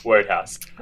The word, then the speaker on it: Wodehouse: Wodehouse? It's a bit like Roadhouse. Roadhouse is a Family Guy. Wodehouse. Is that how you pronounce it? Wodehouse? – Yes. Yeah, Wodehouse.